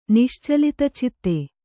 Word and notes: ನಿಶ್ಚಲಿತಚಿತ್ತೆ: niścalita citte

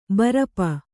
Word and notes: ಬೈರೂಪ: bairūpa

